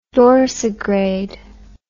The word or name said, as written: dorsigrade